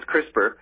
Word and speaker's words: CRISPR: Let's talk about the CRISPR technique. Mm. You said it right. – Oh, good. – They do refer to it as CRISPR, and it's just what we call a genetic engineering technique.